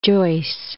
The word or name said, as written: Joyce